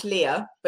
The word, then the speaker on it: Lea: Well, it's pronounced Leah, but you Americans always call me Leah, Princess Leah.